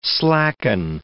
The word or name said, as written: slacken